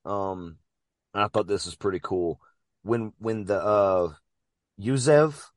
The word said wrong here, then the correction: Yavuz: am I saying it right? – Yavuz.